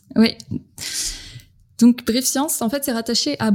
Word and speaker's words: Brief.Science: C'est comme ça qu'on doit le dire, ou est-ce qu'on dit juste Brief Science? – Brief Science. – Brief Science, mais qui s'écrit Brief.Science.